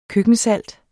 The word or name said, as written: køkkensalt